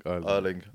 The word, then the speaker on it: Erling: Oh, I could say a bait guy, like Erling Haaland, Haaland, but, like, I can't really... – Erling. You said it right the first time.